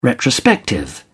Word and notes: retrospective: British English

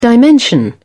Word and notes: dimension: British English

